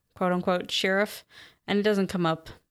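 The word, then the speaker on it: Shirriffs: Yeah, my audiobook pronounced it sheriff. – I don't know how else you would pronounce it besides sheriff because it's got the two R's, but I think it's compounded sheriff and shire together.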